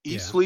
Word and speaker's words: Eastleigh: They beat Eastleigh. Yeah. Is that how you pronounce that? – That is it.